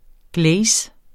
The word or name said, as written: glaze